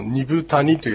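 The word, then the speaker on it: 二風谷: あと先ほどですね、発音したこのニブタニというやつは、はい、まあ、濁らないんですね、実はね。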